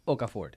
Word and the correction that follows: Okafor: Okafor. – Muy bien, muy bien la pronunciación.